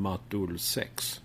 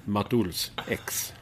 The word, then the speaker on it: Matolsex: Det är ju så konstigt att jag har inte döpt detta namnet själv, utan på Spotify så heter jag M-A-T-O-L-S-E-X, vilket man då kan uttala som Matulsex eller Matulsex. – Ja. Okay. – Det har med Expressen att göra, EX, att det låter så.